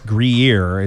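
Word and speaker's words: Gruyère: I think it's Gruyere, it's pronounced, and it's the one you melt to have cheese fondue.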